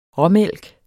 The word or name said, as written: rå